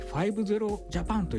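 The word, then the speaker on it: 00000JAPAN: 05 つとジャパンと書いて 50 ジャパンと読むんですけども、面倒な設定やパスワードがいらない Wi-Fi なので、誰でも簡単に使えるようになります。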